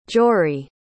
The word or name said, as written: jory